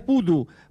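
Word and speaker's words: Bodø: La ciudad es Putu, porque esa O con el palito al medio se pronuncia como una U.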